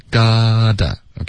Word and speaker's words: gotta: 对，所以这里我们的 you gotta got to，我们就发 gotta，OK，、 okay?